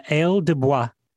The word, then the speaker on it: ail des bois: ail des bois.